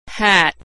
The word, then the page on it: hat: ae